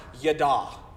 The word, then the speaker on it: yada: It means, it says it's pronounced yada. Okay, say that with me yada.